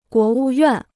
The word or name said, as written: 国务院